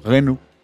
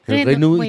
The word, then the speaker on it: Renault: Renault. – Renault, ¿y tú? – Renault.